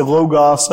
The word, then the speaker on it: logos: I normally don't bother people with Greek, but it's the famous word logos. Or if you want to say logos, that's okay, or however you want to pronounce it.